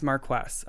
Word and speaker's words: Marquess: No, it's different. It's Marquess. I Googled how to pronounce it specifically. It's the same thing as a Marquis, I think.